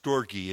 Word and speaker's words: storge: It's pronounced storge, is the love one has for a dependent.